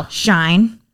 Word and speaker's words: Shein: Shein. – Is it Shein? – Yep.